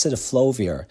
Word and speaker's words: cidofovir: And a medication called citiflovir, yes, it's a difficult one to pronounce, but it's citiflovir. It's spelled CIDOFOVIR, is becoming the drug of choice in viral UTIs because it seems to be active against most common viral pathogens.